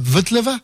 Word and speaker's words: Vltava: traverse Prague, qu'on appelle aujourd'hui la Vltava, pour essayer de le prononcer à la tchèque,